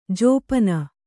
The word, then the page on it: ಜಪ್ಪನೆ: jappane